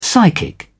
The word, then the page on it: psychic: British English